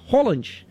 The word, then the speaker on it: Haaland: E o Haaland surpreendeu todo mundo porque na pronúncia se fala Haaland. Erling Haaland.